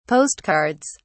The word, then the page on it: postcards: British English